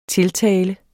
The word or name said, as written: tiltale